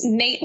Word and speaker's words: Nat: Our first one is Nate Love. It's pronounced Nate, but it's spelled Nat. So it's Nate Love, played by Jonathan Majors, who Nate Love was a skilled cowboy.